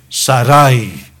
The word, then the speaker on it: Sarai: Y atendió Abraham al ruego de Sarai. Así se pronuncia ese nombre.